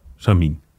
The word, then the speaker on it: Samyn: Het is met een Griekse I. – Ja, en een Griekse ei blijft een I in, eh, in de uitspraak. Ook aan het einde? – Ja. – Voorafgaand aan een uh, N? Ja. Oké. Samin. Samin.